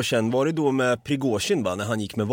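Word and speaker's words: Prigozjin (Пригожин): Jag tror vi säger Prigozjin. – Ja, Prigozjin. – Prigozjin. Det är perfekt uttal.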